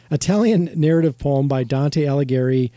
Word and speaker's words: Alighieri: Dante's Allegri's. Uh, how do you say that? Alle- I think it's Alighieri.